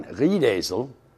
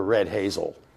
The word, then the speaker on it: Riedesel: And that the wife of the German commanding officer, her name was Madame von Riedesel. The Brits could never pronounce Riedesel. They called her Red Hazel.